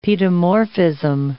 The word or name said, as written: paedomorphism